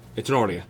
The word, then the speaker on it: Tinordi: Tinordi. – Tinordi.